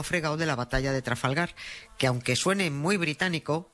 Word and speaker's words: Trafalgar: Que no es Trafalgar, que es Trafalgar. Que una cosa es que los guiris no sepan decirlo, que en su derecho están y no pasa nada, pero otra muy distinta, que un español snob lo pronuncie con acento inglés comiéndose todas las R's.